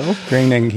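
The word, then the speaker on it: Groening: So Greinig. He says it rhymes with complaining.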